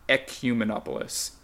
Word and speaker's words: Ecumenopolis: It is Ecumenopolis. E C U M. E N O P O L I S, and I spelled it out because it has the word cum in it.